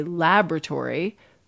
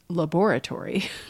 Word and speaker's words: laboratory: But we could also think about a word like laboratory, where the spelling has an additional syllable in it. And in American English, we don't pronounce it. We say laboratory. British English pronounces that syllable because it's laboratory.